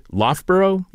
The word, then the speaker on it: Loughborough: She is a professor of political theory at, and I am going to see if I am going to pronounce this correctly, Loughborough University.